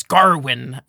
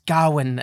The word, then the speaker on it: Gawain: Like, the king says Garwin, and the lady says Gawain, and a bunch of different people say different things.